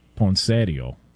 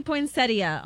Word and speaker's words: poinsettia: Well, the in-laws would call it poinsettio. – Oh, gosh. – What up? You know what I'm saying? Can we get the proper pronunciation on this? – It does say poinsettia online.